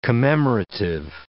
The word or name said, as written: commemorative